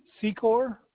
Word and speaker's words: Secor: Blaine Secor.